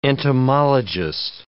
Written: entomologist

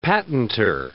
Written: patentor

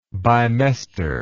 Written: bimester

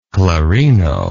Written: clarino

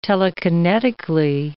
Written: telekinetically

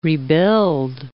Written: rebuild

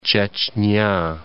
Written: Chechenia

